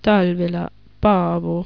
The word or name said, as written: Talvela Paavo